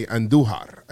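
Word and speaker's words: Andújar: Roll your R's when you say Andujar. I think he's going yard today. Miguel Andujar.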